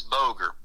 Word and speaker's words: Boger: G-E-R-A-L-D. My last name is Boger, B-O-G-E-R.